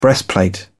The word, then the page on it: breastplate: British English